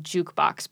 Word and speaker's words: JQBX: Have you ever heard of this thing called I think it's technically called Jukebox, but it's spelled like JQBX.